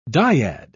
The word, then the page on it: dyad: British English